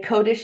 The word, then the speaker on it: Kodesh: So all of my handles are Jodi Kodesh. So it's Jodi, and Kodesh is spelled Kodesh.